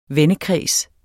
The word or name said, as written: vennekreds